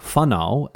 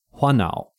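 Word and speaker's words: whānau: This is why, as an example, the F sound, the F sound in Māori, is spelt with a WH, because Hongi Hika, with his particular northern accent, he would have pronounced words like whanau as whanau. And so hearing Hongi Hika say it in this way, it was decided that WH would be the way to represent this sound in a written format.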